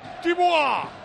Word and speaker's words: Thibaut: No, el problema es decir Thibaut. Pero cómo no ha venido Melchor. Pepe, digo, Tibua.